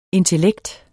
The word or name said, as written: intellekt